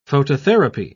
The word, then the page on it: phototherapy: British English